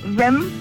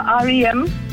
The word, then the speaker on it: R.E.M.: Altså, der er jeg jo så glad for at kunne hjælpe dig, fordi begge dele er egentlig rigtigt, men det er R.E.M., der er det rigtige svar.